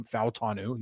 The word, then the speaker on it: Fautanu: Yes, that is the correct way to say his last name, Fautanu. He said it last night.